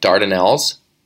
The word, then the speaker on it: Dardanelles: And if you like traditional music, Tom Power from CBC is in a traditional band called, I don't even know if I'm pronouncing it right, Dardanelles. – That sounds right.